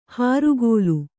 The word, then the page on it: ಹಾರುಗೋಲು: hārugōlu